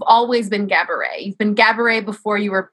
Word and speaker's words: Gabourey: And she says in the book, it's Gabourey, it rhymes with cabaret.